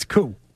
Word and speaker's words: Koo: Nope, it's Koo.